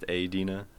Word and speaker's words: Adena: Yeah, just Adena. So like Altadena and Pasadena.